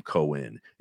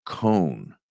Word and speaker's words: Cohn: I don't think he pronounces his last name Cohen. It's Mark Cohn, who sang Walking in Memphis, which is a crazy story.